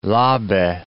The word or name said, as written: Labe